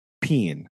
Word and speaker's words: paean: it used the word peen. And I hate it. – Oh. – So much. It's Greek for a song of praise or triumph, but it is spelled P-A-E-A-N.